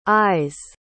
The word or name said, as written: eyes